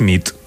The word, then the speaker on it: nit: Y noche es nit. – Muy bien. Buena nit. – Buena nit, es verdad. – Buena nit.